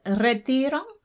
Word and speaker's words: Retiro: How do you say it? – Retiro. – Retiro, the Retiro Park. – Like retired, that's what it is.